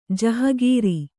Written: ಜಹಗೀರಿ

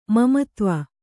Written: ಮಮತ್ವ

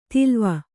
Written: ತಿಲ್ವ

